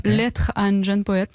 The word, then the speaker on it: Lettre à un jeune poète: How did I do with the pronunciation? – Lettre à un jeune poète. Yeah, Lettre à un jeune poète.